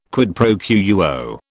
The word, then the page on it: quid pro quo: British English